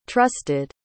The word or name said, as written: trusted